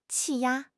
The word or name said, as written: 气压